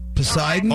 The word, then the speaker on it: Poseidon: Hey, is it Posey Don? – Say again? – What'd you say? I don't know if I'm saying it right. Posey Don? – Poseidon? – Oh, Poseidon Adventure.